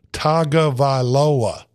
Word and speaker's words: Tagovailoa: And it's pronounced, hey, for any of you people, listen up. It's pronounced Tagovailoa. Okay, remember that, Tua Tagovailoa.